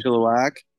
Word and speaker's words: Chilliwack: Chilliwack.